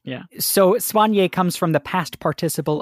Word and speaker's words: Soigné: So Soigné can also be pronounced with the emphasis on the swan part. It can be Soigné, and that's how it's pronounced in French.